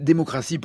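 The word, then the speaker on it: Démocrasse: La plume, c'est démocratie pour revenir sur ce titre. Ça s'écrit comme la crasse et pas avec deux S et pas un T.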